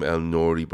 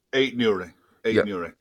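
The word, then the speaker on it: Aït-Nouri: But, you know, you're going to get this stuff in the papers and stuff like, I want to say his name, El Nouri, but I don't think it's right. The left back off Wolves. – Eight Nouri.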